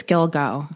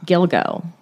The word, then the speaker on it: Gilgo: Is that how it's pronounced? – I think it's Gilgo. – Gilgo.